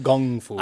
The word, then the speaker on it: Kung Fu: Okay, so in Cantonese, the term of Kung Fu should be pronounced Kung Fu, Kung, it's a G, Kung Fu. But the Brits, in their kind of nonsensical way of transliterating stuff, write that as a K, all right? And so the K-U-N-G is a Britishism from the colonial times in Hong Kong.